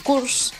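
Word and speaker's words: course: Y pues yo le dije, I'm going to study a cures. – Sí. – Y se ve que pronuncié mal la palabra cures. – Ajá. – Porque no, un curso no se dice cures, se dice curse.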